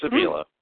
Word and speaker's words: Avila: It's Avila. – Avila. Okay. – Mm-hmm. – Avila. You know, he's the GM, and Ilitch liked him, so I understood why he wanted Avila, and that's fine.